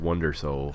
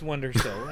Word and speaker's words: WNDR Soul: That's probably pronounced Wonder Soul. – Oh, yeah, I know it's Wonder Soul. I just wanted to spell it out so you can find him.